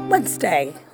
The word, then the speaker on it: Wednesday: A lot of us think that it's actually three syllables, but it's just two. Wednesday. Wednesday. Don't go saying Wednesday. Wednesday. Wednesday. So that tells you that the D is silent.